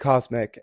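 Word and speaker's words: CosMc's: It's called Co it's just called Cosmic, and it's spelled COSMC.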